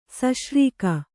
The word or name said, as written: ಸಶ್ರೀಕ